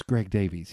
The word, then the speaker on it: Davies: It is Greg Davies.